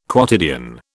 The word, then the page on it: quotidian: British English